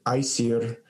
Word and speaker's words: Aesir: It was the first battle is between the Aesir gods, or actually I'll pronounce it Aesir. That's how I've heard Carolyn Larrington. I listen to her pronunciations very carefully and use them as much as possible because she's obviously an authority. So she calls it Aesir.